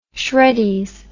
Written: Shreddies

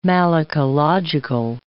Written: malacological

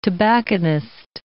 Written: tobacconist

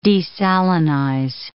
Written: desalinize

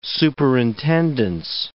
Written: superintendence

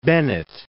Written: Bennett

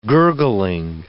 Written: gurgling

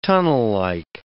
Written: tunnellike

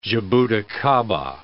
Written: jaboticaba

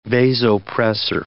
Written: vasopressor

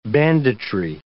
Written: banditry

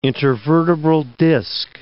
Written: intervertebral disk

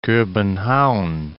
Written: Kobenhavn

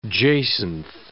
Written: jacinth

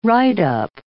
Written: write-up

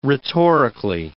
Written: rhetorically

